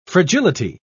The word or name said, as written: fragility